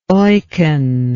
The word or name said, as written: eucken